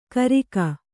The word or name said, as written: ಗರ್ಗ